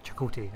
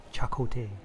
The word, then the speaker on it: Chakotay: You said his name wrong. – What did I say? – You went Chakotay. It's Chakotay.